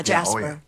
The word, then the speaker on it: Jasper: That's a hard J, though, right? – Yeah, Jasper.